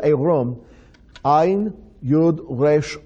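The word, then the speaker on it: Erom (עירם): It's pronounced Erom. Erom is Ayin Yud Resh Mem. Erom.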